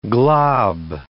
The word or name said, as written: glob